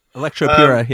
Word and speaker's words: Electropura: Unless anyone's got anything else to say about Painful, I'm going to move us on to, how do you pronounce it? Electropura? – Electropura, yeah.